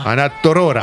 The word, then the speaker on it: Torroja: Ana Torroja.